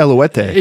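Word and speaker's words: Eluveitie: And they do like Bellicor and Eluvite, which is not how you pronounce Eluvite. – No, it's Eluete. – Yeah, but it's not even with the U. It's just Eluete.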